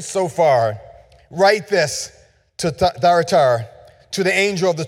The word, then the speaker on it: Thyatira: So this morning we want to look at the church of Thyatira. I'm pronouncing that wrong. Thyatira.